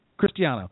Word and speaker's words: Christiano: How do you pronounce it? Christiano, right? – Christiano.